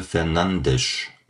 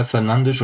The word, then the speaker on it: Fernandes: Und dann sagte er, und ich zitiere ihn jetzt, er sagte, kein Portugiese würde dich anrufen, würde dich an den Pranger stellen, würde dich beschimpfen, wenn du ganz normal Fernandes sagst. Weil dieses Fernandes kannst du nicht aussprechen. Kannst du nicht. Weil du bist kein Portugiese. Du hast die Zungenakrobatik nicht dafür. Oder das Timbre oder whatever. Also bleib bei Fernandes und du machst alles richtig. Und das gibt mir natürlich dann die Sicherheit,